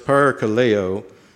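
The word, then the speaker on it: parakaleo (παρακαλέω): The Greek is pronounced parakaleo, meaning to call to one's side, to admonish and exhort.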